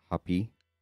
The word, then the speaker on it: Hapi: God of the water was, I think that's pronounced Hapi. H-A-P-I.